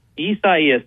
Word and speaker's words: Isaias: Walk me through, before we get to anything else, Google tells me it's pronounced Isaias, but I've been hearing other pronunciations as well, pronunciations that add syllables that I don't think need to be added. How are y'all pronouncing the storm? – Isaias. That is the phonetic pronunciation there in the National Hurricane Center's name list guide.